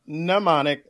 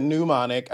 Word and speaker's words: mnemonic: Uh, uh, mnemonic. You said mnemonic as if it was like pneumonia.